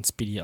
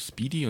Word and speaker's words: SPDY: Also S-P-D-Y. – Ja, ich glaube, spricht man Speedy aus.